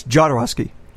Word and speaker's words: Jodorowsky: Todorovsky's Dune? Is that how it's pronounced? – Yes, Jodorowsky. – Jodorowsky. I always say Todorovsky, and I don't know why.